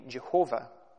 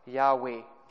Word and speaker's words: Yahweh: Until relatively recently, it was widely regarded that the pronunciation would have been Jehovah. But modern scholars have proved this to be an error, and the pronunciation is more along the lines of Yahweh.